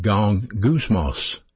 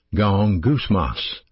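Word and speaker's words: gong gousmos (γογγυσμός): Complaining is a Greek word pronounced gong gousmos. Gong gousmos. That's a weird word, I know, but it's translated murmuring.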